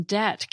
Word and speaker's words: debt: This is where the silent B in debt came from.